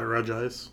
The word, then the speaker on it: Regice: How do you say it? – I call it Regice. – Me too. That's how it reads to me.